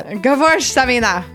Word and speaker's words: Gewürztraminer: Gewurztraminer. There we go. – You have to say it like you're German. – Jason's all, you're gonna screw it up. – Gewurztraminer.